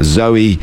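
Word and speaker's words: Zawe: I was talking about Zoe, and it's spelled differently. It's Z-A-W-E Ashton.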